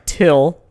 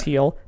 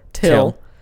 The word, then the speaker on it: Thgil: His name is established as Teal, which is T-H-G-I-L, but pronounced, sorry, Till, not Teal, Till.